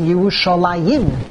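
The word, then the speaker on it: Yerushalayim (ירושלים): We pronounce it, Kriv Chesed, we pronounce it Yerushalayim. Yim, Yud Mem.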